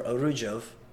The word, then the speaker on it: Arujau: Yeah, so, I mean, it's all Arujau or Arujov. Right? But it's spelled differently.